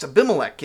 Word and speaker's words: Abimelech: Most tellingly, he bears a son who either at birth or later in life comes to be named Abimelech, usually pronounced Abimelech in English, a name which literally means, "My father is king."